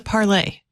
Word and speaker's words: Parler: Is it Parler or is it Parlay? – I was thought it was Parler. – It's E-R.